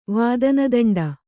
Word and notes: ವಾದನದಂಡ: vādana daṇḍa

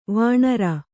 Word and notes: ವಾಣರ: vāṇara

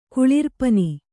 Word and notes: ಕುಳಿರ್ಪನಿ: kannada

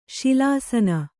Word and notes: ಶಿಲಾಸನ: kannada

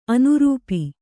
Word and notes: ಅನುರೂಪಿ: anurūpi

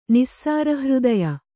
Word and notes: ನಿಸ್ಸಾರಹೃದಯೆ: kannada; nissāra hřdaye

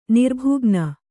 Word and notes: ನಿರ್ಭುಗ್ನ: nirbhugna

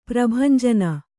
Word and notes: ಪ್ರಭಂಜನ: prabhanjana

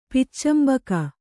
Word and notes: ಪೊಚ್ಚಂಬೋಗು: poccambōgu

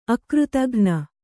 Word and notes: ಅಕೃತಘ್ನೆ: kannada

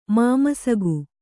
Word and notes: ಮಾಮಸಕ: māmasaka

